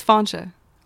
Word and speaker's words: Fainche: It's Fauncha. Like Slauncha. F-A-F-A-I-N-C-H-E.